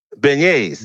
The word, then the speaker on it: beignets: Those. Those bagnets. Beignets. Beignets. Yeah, Cafe Du Monde.